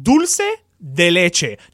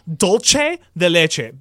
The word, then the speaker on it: dulce de leche: And it's called dulce de leche, not dolce de leche, because no, Italians don't have a ch. The c with a vowel is a ch.